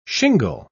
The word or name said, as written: shingle